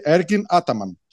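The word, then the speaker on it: Ergin Ataman: Γιατί αυτό το προφέρει Έρκιν Άταμαν. – Σωστά. Έχεις απολύτω δίκιο. Και αυτό είναι το σωστό.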